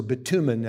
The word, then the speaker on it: bitumen: Now it says the valley of Siddim was full of bitumen pits, or bitumen actually is how that's pronounced.